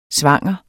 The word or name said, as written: svanger